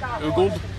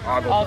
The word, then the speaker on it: ogled: Is that how you pronounce that word? – Ogled? – It was like fucking... Oogled? – Ogled. Yeah, it's ogled.